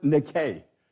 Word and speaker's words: Nike: It's a part of the word nike. We spell that N-I-K-E, and we pronounce it Nike.